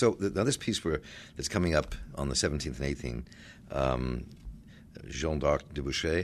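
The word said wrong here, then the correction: Jeanne d'Arc au bûcher: did I say that correctly? – Jeanne d'Arc au Boucher. – Au Boucher, yes.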